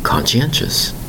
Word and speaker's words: conscientious: They are called being very conscientious. Spelled C-O-N-S-C-I-E-N-T-I-O-U-S. Conscientious. Four distinct syllables.